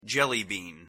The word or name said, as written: jellybean